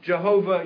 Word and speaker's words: Jehovah: His very name, Jehovah Yahweh, by the way, same name. Like I said, we don't know how to pronounce it, so those two are both acceptable.